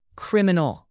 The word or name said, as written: criminal